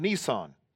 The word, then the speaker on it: Nisan: It's called Nisan, and you pronounce it just like the car that a lot of you drive.